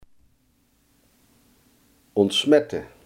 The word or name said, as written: ontsmetten